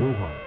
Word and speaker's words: Wuhan: Wuhan.